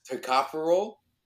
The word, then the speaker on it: tocopherol: Tocopherol? – That's the one.